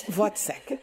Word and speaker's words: Wozzeck: Wozzeck.